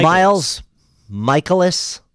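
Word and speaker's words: Mikolas: Is it Mykolas or is Mykolas? Is it like Nicholas but with a my at the front? I thought it was Mykolas. – Miles Mykolas. It's definitely my. – Right. – So it's Miles Mykolas, I would say. Yeah, yeah. Why I don't... – Because it's spelled like Nicholas with an M.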